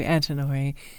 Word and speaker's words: Antinori: It's a name even I recognize to Antinori.